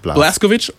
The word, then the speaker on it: Blazkowicz: Der führt die Geschichte fort von BJ Blazkowicz, hab ich das richtig ausgesprochen? – Blazkowicz und seinem Kampf in einer Welt, in der die Nazis den Krieg gewonnen haben.